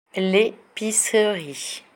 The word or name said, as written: l'epicerie